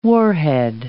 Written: warhead

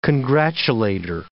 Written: congratulator

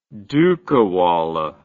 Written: dukawallah

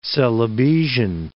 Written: Celebesian

